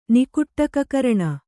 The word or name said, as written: ನಿಕುಟ್ಟಕಕರಣ